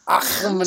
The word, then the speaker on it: Achim: Achim and, you gotta say it right, Achim and Rezar.